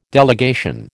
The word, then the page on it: delegation: American English